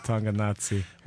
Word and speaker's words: Tonganoxie: We got Jesse Leonard from Tonganoxie, Kansas. I hope I'm pronouncing it right. – Sure. It's right near Billerica. Tonganoxie.